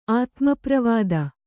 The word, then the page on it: ಆತ್ಮಪ್ರವಾದ: Kannada